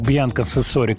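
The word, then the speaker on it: Bianca Censori: – вот, я посмотрел на его барышню, э, вот это Бьянка Сенсори, угу. читается так, Бьянка Сенсори, которая при каждом удобном случае засвечивает булки свои и прочие всякие радости.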